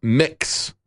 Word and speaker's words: Mx: My prefix is MX, pronounced mix.